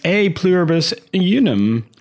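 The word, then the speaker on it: E Pluribus Unum: A Pluribus Unum. Is that correct? Is that how you say it? – That is correct.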